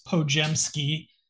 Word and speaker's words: Podziemski: Kareth Burke saying that we're pronouncing this Pojemski.